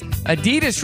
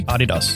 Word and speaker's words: Adidas: And Americans pronounce Adidas wrong. It's supposed to be Adidas. – Adidas, yes. Which David, who is from Australia, he said that the last time he was on the show.